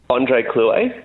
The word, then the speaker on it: André Clouet: André Clouet, that's right.